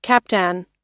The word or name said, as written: captan